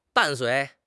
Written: 伴随

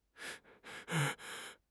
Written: 服从

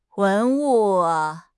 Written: 文物

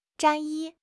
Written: 战役

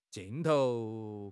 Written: 镜头

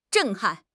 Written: 震撼

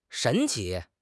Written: 神奇

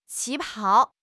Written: 旗袍

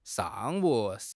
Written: shang4 wu3

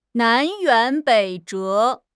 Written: 南辕北辙

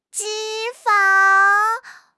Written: 脂肪

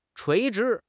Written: 垂直

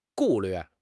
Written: 顾虑

